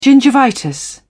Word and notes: gingivitis: British English

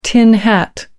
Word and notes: tin hat: American English

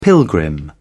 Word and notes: pilgrim: British English

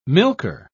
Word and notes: milker: British English

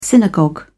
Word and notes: synagogue: British English

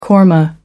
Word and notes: korma: American English

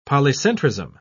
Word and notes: polycentrism: British English

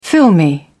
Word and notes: filmy: British English

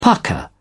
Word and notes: pukka: British English

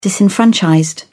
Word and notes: disenfranchised: British English

